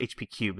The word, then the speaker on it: HP3: HP3, also pronounced HP Cube, is the official pronunciation, HP Cubed.